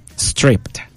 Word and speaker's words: Stripped: Stripped, para los que lo mencionan, así como lo pronunciábamos antes de saber inglés. – ¿Y cuando sabes inglés, cómo se pronuncia? – Stripped.